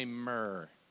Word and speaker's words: myrrh: Can you say myrrh? Now can you kind of roll your R, myrrh.